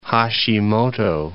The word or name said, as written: Hashimoto